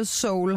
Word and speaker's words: Seoul: Er der, lige, der er lige et E i, som jamen, vi udtaler Seoul, har du lært mig i dag.